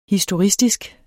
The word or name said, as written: historistisk